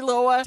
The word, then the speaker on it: Loa: Sorry, Loa. – Down the, I believe it's not far from Fish Lake, actually. – I know it's spelled L-O-A. – That's right. – How's that? – That's right. – It's not L-O-W-A. Okay.